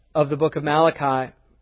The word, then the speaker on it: Malachi: It's pronounced Malachi. He's not Italian. It's not Malachi.